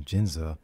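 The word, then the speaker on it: Ginza: But then when she goes to, I believe it's pronounced Jinza, she's going to work at a new job, and then all of a sudden Godzilla shows up and he's massive, you know.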